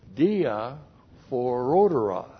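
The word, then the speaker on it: dia phororos (διαφορώτερος): This is D-I-A-P-H-O-R, long O, T-E-R-O-S. dia phororos.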